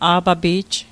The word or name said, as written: a'papi'j